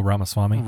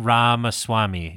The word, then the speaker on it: Ramaswamy: Well, didn't that I can't pronounce his name. Rashwani? – Oh, Ramaswamy. – Ramaswamy, yeah. Didn't he come up with like ten commandments that he's running on?